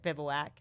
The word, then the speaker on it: Bivouac: Like everybody, the first question everybody asks, how do you pronounce it? Bivouac.